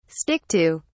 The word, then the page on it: stick to: British English